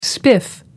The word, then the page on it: spiff: American English